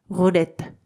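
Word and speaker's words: roulette: ただしですね、このルーレットに関しては、ルーレットのレの部分は L ななので、フランス語発音ですと、ルーレット、ルーレットになります。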